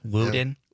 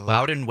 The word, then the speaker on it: Loudon: I don't know how you say L-O-U-D-O-N. Loudon? Loudon? Yeah. Loudon Wainwright III.